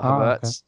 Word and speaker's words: Havertz: Although I was watching actually during a watch along with a German, a fellow, and he actually told me how to pronounce him correctly. So the English version of his name is not correct, but it doesn't really matter. – Uh-huh. – So it's Havertz, not Havertz.